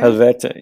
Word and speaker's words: Helvete: So is the bookstore Helvete, is that how you pronounce it? Is that still there? – Helvete, yeah.